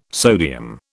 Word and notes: sodium: British English

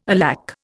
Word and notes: alack: Indian

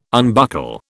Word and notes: unbuckle: British English

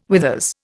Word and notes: withers: Indian

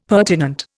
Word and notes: pertinent: Indian

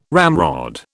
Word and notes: ramrod: British English

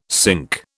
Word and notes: cinque: British English

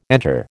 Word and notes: enter: American English

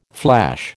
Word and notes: flash: American English

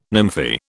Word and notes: nymphae: British English

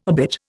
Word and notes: obit: Indian

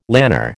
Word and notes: lanner: American English

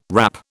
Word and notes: wrap: British English

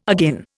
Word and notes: agin: Indian

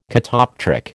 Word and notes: catoptric: American English

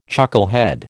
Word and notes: chucklehead: American English

